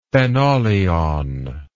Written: phenolion

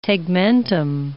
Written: tegmentum

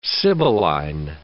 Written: sibylline

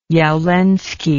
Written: jawlensky

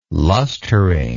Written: lustering

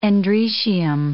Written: androecium

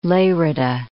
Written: Lerida

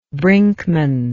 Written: brinkman

